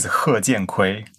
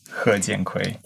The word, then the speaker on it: He Jiankui: His name is He Jianqui. He Jianqui.